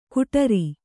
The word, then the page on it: ಕುಠಾರಿ: kuṭhāri